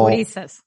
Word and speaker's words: gurises: Ah, me gusta hasta las palabras que dice que aprendí allá, que se llama, a ver si lo digo bien, eh, jurises, ¿no? Que es como... – Gurises. Gurises. gurises.